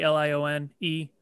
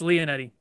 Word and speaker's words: Lionetti: Instagram, matt.lionetti, spelt or pronounced Lionetti, LIONETTI. – Have I been saying that wrong the entire time too? – No, it's Lionetti, but everyone pronounces it Lionetti.